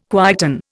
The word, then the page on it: quieten: Indian